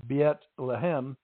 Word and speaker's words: Bethlehem: In Hebrew, the city's name is pronounced Bethlehem.